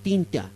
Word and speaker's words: tincha: dice, Tigtincha, tincha, perdón, la pronuncio bien, la tincha